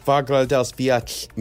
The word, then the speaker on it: Fagradalsfjall: Fagradalsfjall Mountain.